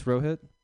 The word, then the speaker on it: Rohit: I always hated roll call growing up because, like, my name is only two syllables. It's Rohit.